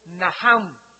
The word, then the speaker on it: Nacham: La primera palabra que se usó en el Antiguo Testamento es Naham, en el hebreo que se escribe Nacham. La CH en el hebreo se pronuncia como J.